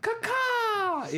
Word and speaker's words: Kaká: Brazil doesn't even have Kaká anymore. – That is the correct pronunciation. Yes.